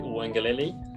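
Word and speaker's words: Uiagalelei: ¿Cómo se pronuncia su apellido, amigos? – DJ Wangelele.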